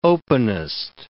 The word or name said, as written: openest